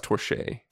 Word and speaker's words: Torche: It's actually pronounced Torche. – Torche, okay.